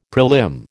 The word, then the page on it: prelim: American English